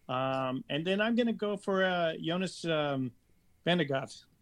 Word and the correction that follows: Vingegaard: Yeah, Jonas Vingegaard.